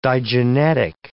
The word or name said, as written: digenetic